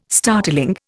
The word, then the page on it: startling: Indian